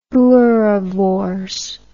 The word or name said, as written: boerewors